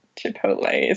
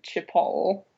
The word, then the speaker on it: Chipotle: So we might have Brexit, but we've got Chipotle. Is that how you say it? – It is. – I used to read it Chipotle. That's not right.